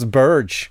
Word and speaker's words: Berge: Men han själv säger att nej, det uttalas Burge. Nej, Sander Burge. – Det gör det inte. – Det har de slutat med nu. Alltså nu säger de faktiskt Berge eller Burge.